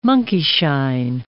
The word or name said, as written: monkeyshine